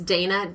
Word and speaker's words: Dayna: It's Dana, D-A-Y-N-A.